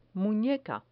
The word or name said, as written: muneca